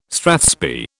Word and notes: strathspey: British English

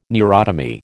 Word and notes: neurotomy: American English

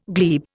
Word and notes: glebe: Indian

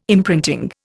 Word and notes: imprinting: Indian

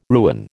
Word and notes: ruin: American English

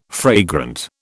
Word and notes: fragrant: British English

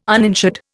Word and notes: uninsured: Indian

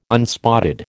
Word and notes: unspotted: American English

